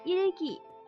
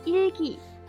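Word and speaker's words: 일기: 在韩语里，日记念作 일 기，不知道大家有没有写日记的习惯呢？有的话就学一学这个词吧。日记， 일 기， 일 기。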